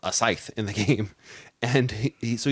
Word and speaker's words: Scythe: From Stonemaier Games, Scythe. And yes, it's pronounced Scythe, not Scythe. – It's not Scythe, it's Scythe. – It's not Scythe, Jason.